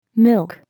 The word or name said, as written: milk